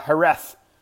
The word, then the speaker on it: Jerez: And you wonder why I'm saying it with a T-H at the end, because that's how they say it. Now, it's spelled J-E-R-E-Z, but that's not how they say it at all. It doesn't make any damn sense, but it is. It's Jerez. Silent J, and then instead of Z, they pronounce a T-H.